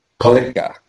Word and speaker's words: Pelinka: Pelinka.